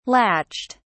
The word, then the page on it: latched: British English